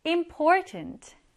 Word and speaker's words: important: Important, important, 중요한이라는 뜻입니다. 강세는 for 이 부분에 들어가게 돼요.